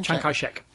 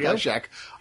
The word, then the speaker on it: Chiang Kai-shek: Chiang Kai-shek. – Thank you. – There Chiang Kai-shek.